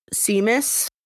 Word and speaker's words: Seamus: Kind of the only important thing is that with Jordan and Seamus.